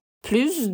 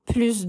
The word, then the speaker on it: plus: Aqui o S se pronuncia. Viram a diferença? A gente também tem uma outra situação que o S de plus é pronunciado. Quando a gente estiver se referindo à adição, à continha matemática mesmo, sempre falamos o S do plus.